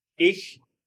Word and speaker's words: Ich: So it's not ich, it's E. – E.